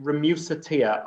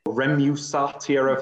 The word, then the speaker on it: Remusatia: you say it Remusatia, R E M U S A T I A. Remusatia, I've heard people say, but I don't like that pronunciation.